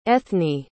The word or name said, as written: ethni